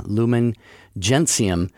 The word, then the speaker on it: Lumen Gentium: L-U-M-E-N is Lumen, and Gentium, G-E-N-T-I-U-M, Lumen Gentium. – That's right. – Is how you pronounce it, but it's a Latin, the Latin.